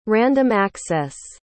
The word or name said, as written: random access